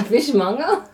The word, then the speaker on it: fishmonger: A fishmonger? – A fishmonger. – Like someone who just mungs on fish? – Just mung that fish away. – That is actually how it's said.